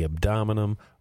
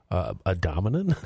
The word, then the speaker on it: abdomen: People may experience pain in the abdomen. Abdominum? – I don't think that's a word.